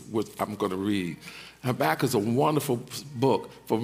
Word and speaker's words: Habakkuk: And please, if my pronunciation is different, that's how we say it in the hood. We say Habakkuk.